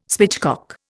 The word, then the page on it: spitchcock: Indian